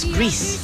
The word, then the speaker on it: Grease: se emocionó hasta las lágrimas al recordar a su entrañable amiga y coprotagonista en Grace. O Grease, ¿cómo se dice? Creo que es Grace. No, es Grease, porque Grace es el nombre.